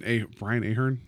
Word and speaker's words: Ahern: Brian Ahern is how you pronounce it anyway. – That's how I said it.